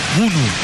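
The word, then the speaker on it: Bounou: Discúlpeme, Mariano, si le digo Bono, porque el traductor que nosotros tenemos en Golan Pop me dijo Bunu. Pero bueno. – Sí, es correcto, pero está, digamos, desnaturalizado en una pronunciación directamente como Bono y ya está institucionalizado. Pero es bien, es correcto mencionarlo como Bono.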